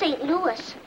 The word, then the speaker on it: St. Louis: Strongest ice horse in St. Louis. – Excuse me, Mr. Neely, but it's pronounced St. Louis. – Is it now? I got a cousin spells it the same way, and we call him Louis.